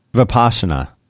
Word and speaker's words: Vipassana: Is that how you pronounce it? Vipassana? – Vipassana. – Well, he's a Vipassana guy. – That's how I've always heard it pronounced.